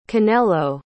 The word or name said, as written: Kanelo